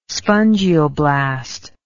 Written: spongioblast

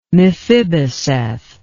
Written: miphiboseth

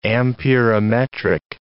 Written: amperometric

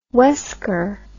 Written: wesker

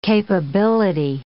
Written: capability

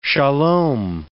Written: sholom

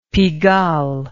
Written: Pigalle

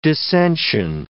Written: dissension

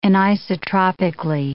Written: anisotropically

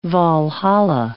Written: Walhalla

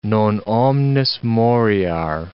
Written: non omnis moriar

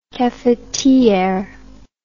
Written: cafetiere

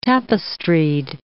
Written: tapestried

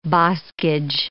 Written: boscage

